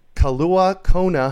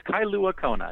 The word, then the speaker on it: Kailua-Kona: It's Kalua Kona, just to correct the pronunciation there.